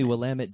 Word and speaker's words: Willamette: I just returned from a trip up to Oregon's Willamette Valley, and we just... – Okay, so that's how you say it. Willamette. – Yes. Not someone told me Willamette Dammit is how you remember it.